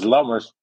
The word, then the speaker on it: Lammers: In Dutch? I always it's Lammers, but I always thought if ever I go to America, then Lammers means lamb, So it doesn't mean, but it sounds the same.